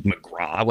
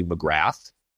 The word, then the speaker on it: McGrath: I just don't like that people pronounce it McGraw when it's clearly McGrath.